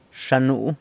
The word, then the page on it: Xa nuu: sanlucasquiavini